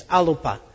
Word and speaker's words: Alba: You can go online, it's free, to BBC Alpa, A-L-B-A. It's pronounced like it has three syllables, Alpa, but it only has two. A-L-B-A is the old Gaelic name for Scotland, Alpa.